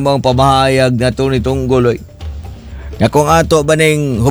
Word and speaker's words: Tunggol: Attorney Reginald Tunggol.